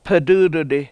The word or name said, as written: ped!!d1di